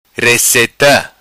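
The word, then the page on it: Receta: patua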